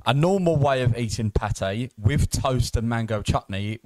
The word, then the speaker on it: pâté: The pâté, is that how it's pronounced? – Yes.